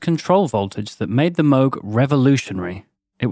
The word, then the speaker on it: Moog: Most of you have probably heard his name before, although you might have heard it pronounced as Moog. Moog. Moog. Moog. But it's definitely pronounced Moog. Trust us on this one.